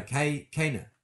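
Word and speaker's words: caganer: It says the pronunciation, the Catalan pronunciation is Cayenne. Cayenne. Oh, Cayenne. – Like Kanye, but not. – Cayenne. – Cayenne. No, Kana. Kana. Kana. The G is silent.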